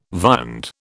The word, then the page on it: viand: British English